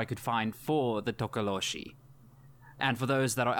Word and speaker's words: Tokoloshe: You know what? A Tokoloshe. – Yeah, that's actually good. Good one.